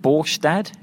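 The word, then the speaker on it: Båstad: He has not won a match, Nicolas Jarry, since July, when he won the title in, is it Borstad? Is that how you say it? – That's right.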